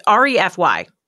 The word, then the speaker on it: REFY: Re- REFY or it's R E F Y.